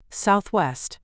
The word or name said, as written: Southwest